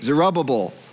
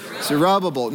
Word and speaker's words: Zerubbabel: Now, I did some research. Some people pronounce it Zerubbabel. Probably the actual way it was technically perfect pronounced would be somewhere in between Zerubbabel and Zerubbabel, but that's hard to do, so I'm just going with Zerubbabel.